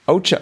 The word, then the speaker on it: ocho: Ocos. – Ocho. – Ocho, yeah, exactly. – Cuatro plus cuatro. – Yep. – Ocho. – I now know how to say the number 8 in Spanish.